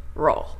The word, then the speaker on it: natural: これを英語の発音にすると、ナチュ natural natural 最後の RL が同じなんですね。